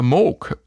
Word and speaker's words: Moog: Der legendäre Synthesizer Moog wird nicht Moog und Gibson, nicht Gibson ausgesprochen.